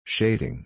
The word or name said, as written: shading